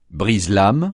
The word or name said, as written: brise-lames